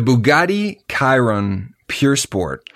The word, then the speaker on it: Bugatti Chiron Pure Sport: I'm excited to hear how you pronounce this car. – The Bugatti Chiron Pure Sport. – Oh, damn, that was pretty good. – That was good. – Pure is spelled P-U-R, though, so it could be purr.